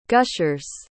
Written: gushers

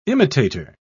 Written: imitator

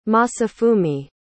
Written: masafumi

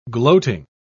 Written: gloating

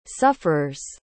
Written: sufferer's

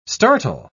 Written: startle

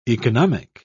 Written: economic

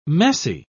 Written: messy

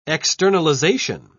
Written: externalization

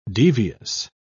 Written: devious

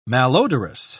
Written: malodorous

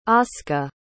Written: asuka

